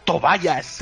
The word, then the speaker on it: Tobias: A mí, perdonadme, pero me gusta la pronunciación en inglés de Tobias, que es Tobayas.